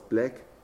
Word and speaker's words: Blake: Wie spricht man die eigentlich aus? Black. – Ja. – Black, genau. Wie der schwarze Kaffee.